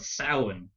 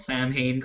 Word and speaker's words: Samhain: But it's a mispronunciation of Samhain, which is the Irish word for Halloween. – Okay. – And it has bugged me forever, because everyone in America calls it Samhain.